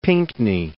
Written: Pinckney

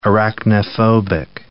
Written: arachnophobic

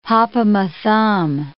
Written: hop-o'-my-thumb

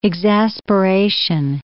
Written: exasperation

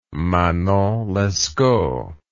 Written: manon lescaut

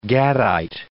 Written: Gadite

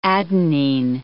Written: adenine

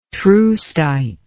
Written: troostite

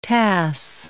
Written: tasse

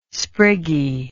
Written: spriggy

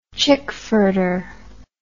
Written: chickfurter

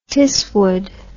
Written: tisswood

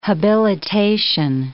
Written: habilitation

